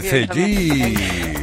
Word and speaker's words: Cegid: Y como decía, se dice Zellit, pero se escribe Zellit con G.